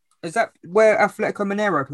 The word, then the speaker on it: Mineiro: It's pronounced Mineiro. Ronaldinho played. – I wasn't far off.